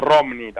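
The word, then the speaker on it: Romny: Bueno, él nació en Romne, o Romni también se pronuncia, en Ucrania, en 1899.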